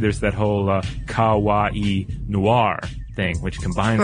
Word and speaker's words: kawaii: She says, "Hey guys, I just wanted to let you know that in Japanese, kawaii, K-A-W-A-I-I, well, that's the, you know, the English version of the kanji, cute is pronounced ka, like the kaing of a crow. So ka. Ka, like the cawing of a crow. Wa is in water, and I as in the letter E. So kawaii, like Hawaii.